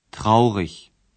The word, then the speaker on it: traurig: Outros adjetivos você reconhece pela terminação i-g, que se pronuncia como ich. Traurig.